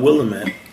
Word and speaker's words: Willamette: You um, so you're in the Willamette Valley. I always call it Willamette. How do you pronounce it? Willamette. Willamette. Mm-hmm.